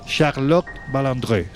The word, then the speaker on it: Charlotte Balandré: Un ejemplo sería el de la actriz francesa Charlotte Balandré. ¿Se dice así? – Sí.